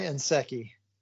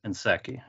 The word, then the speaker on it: Nsekhe: Ty Nsekhe. – Nsekhe.